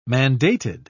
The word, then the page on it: mandated: British English